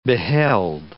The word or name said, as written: beheld